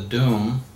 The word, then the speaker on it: Dum: it's called the Dum, D-O-M, but it's spelled D-U-M, the Dum Diversus.